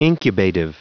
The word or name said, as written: incubative